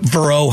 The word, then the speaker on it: Verot: It's called Bishop Verot High School, spelled like Ross Perot, Bishop Verot High School in Fort Myers, Florida.